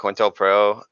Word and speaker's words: COINTELPRO: Compro? – Yeah, like, COINTELPRO. – COINTELPRO, yeah.